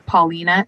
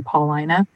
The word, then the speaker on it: Paulina: They said Paulina, and it's supposed to be Paulina, which is one of those that, like, you know, it's a very specific Chicago thing.